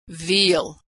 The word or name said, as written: veal